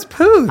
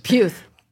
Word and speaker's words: Puth: No, it's Puth. – Oh, whatever. Oh, it's spelled the same. – It's P-U-T-H. – Puth.